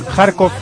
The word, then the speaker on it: Kharkov: Se cruzan en Kharkov, que me ha dicho Pedro Martín, que es así como se lee. – Sí, porque se escribe Kharkiv, ¿no? – Sí, pero no. – Se dice Kharkov, me ha dicho Pedro Martín. – Kharkov, él sí que es Kharkov. – Sí, él sí que es Kharkov. – No, se dice así, Kharkov.